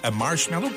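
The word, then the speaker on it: Marshmello: La preuve que vous êtes au meilleur de la musique, c'est Marshmallow, comme ça qu'on le prononce. – Ouais, tu l'as bien.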